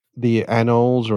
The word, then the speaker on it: anole: How do you say it? – Anoles. – Do you say anole or anole? – Anoles. Yeah, the big ones, the night.